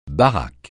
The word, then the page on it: baraque: francais